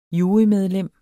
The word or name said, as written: jurymedlem